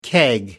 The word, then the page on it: keg: American English